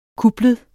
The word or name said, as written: kuplet